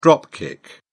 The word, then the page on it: drop kick: British English